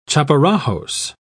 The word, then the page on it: chaparajos: British English